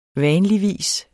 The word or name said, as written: vanligvis